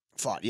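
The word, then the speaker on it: Pfaadt: How do you say Brandon Fott? – Fought, yeah, okay, so that's how you say his name.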